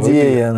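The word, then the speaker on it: IDEA: Идея называется. А, так, то есть пишется по-другому? Да, конечно.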